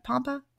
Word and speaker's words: Pompa: I was listening to a podcast on Ben Greenfield Fitness where he had Dr. Dan Pompa. Is that how you say it? Pompa? Yes.